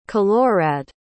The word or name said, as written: kilorad